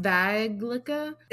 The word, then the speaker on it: Vaglica: Or it's pronounced Vajlicka. It's spelled V-A-G-L-I-C-A. – Is this a fucking BuzzFeed article you're reading? – No, it's not.